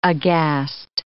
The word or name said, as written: aghast